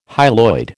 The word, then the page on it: hyaloid: American English